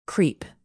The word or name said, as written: creep